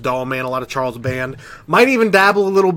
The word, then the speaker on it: Charles Band: A lot of Charles Band. I did say it right. Danielle, I said it right. Charles Band.